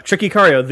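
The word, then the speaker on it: Tchéky Karyo: Yeah, so this movie saw, as Mike mentioned, this movie saw a return of, how did you say his name? Check Icario? – Yeah, Check Icario, the French guy who's the tough French guy in all the 90s movies where Jean Reno isn't the tough French guy.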